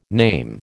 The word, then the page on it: name: American English